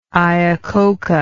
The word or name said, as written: iacocca